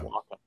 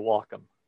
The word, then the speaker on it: Wacom: So I think he said Wacom, because it's like what you do with your dog, you Wacom.